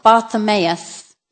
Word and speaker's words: Bartimaeus: In Mark 10, 46 to 52, we can read the story of Bartimaeus, the blind man who... Thank you. I didn't think I'd said it right. Yeah, Bartimaeus. I need to check long words before I read them.